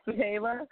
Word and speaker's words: Zuhiela: I'm just Zuhiela.